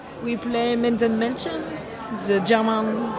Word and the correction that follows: Minden: Ah, Minden Mädchen. Yes, yeah. sorry yeah. For, the, for the pronunciation.